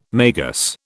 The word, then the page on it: magus: British English